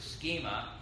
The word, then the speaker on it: schema: Scheme comes from a Latin word, which means, it's pronounced a schema.